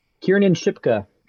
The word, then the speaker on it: Kiernan Shipka: Kiernan Shipka, Sabrina from the Netflix Sabrina the Teenage Witch.